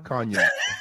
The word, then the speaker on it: cognac: Oh, the G silent? Why you got me saying it wrong all this time, man? – I just let you rock with it. They know you know. They know. They. It's you. You Ocho. – Hold up. How you say it? Say it again. – Huh? Cognac. Yeah. – Okay, you said cognac, but it's spelled C O G N A. Why not a Y? Because you're saying yak. Cognac. C O N Y A C.